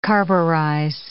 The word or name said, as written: carburize